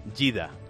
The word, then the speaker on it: Jeddah: Jeddah. – Vale, ellos lo pronuncian Jeddah.